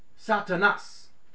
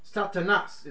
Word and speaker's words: Satanas: This Satan word, 4567, on the Greek side of your Strong's Hebrew Greek dictionary, it means, it's pronounced Satanas. Satanas is how you pronounce it.